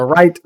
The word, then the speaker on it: Wright: Los hermanos Reed. Reed los, papás de la aviación, no sé cómo se dice ese apellido, es Wilbur y Orville. – Wright, los hermanos Wright.